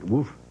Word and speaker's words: WFF: and I told you that they pronounce it woof. And that's the way I learned to pronounce it from Wilfred Sellers, philosopher. And now I find that my colleagues in the Philosophy of Science Center tend to say woof with an I rather than woof.